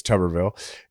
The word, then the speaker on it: Tuberville: talked last week, uh, you know, about Tommy uh, Tuberville, which a lot of people told me I was pronouncing it wrong. It's Tuberville, and his foolishness, acting like uh, the reason that, that that like abortion policy should affect whether or not we elevate generals.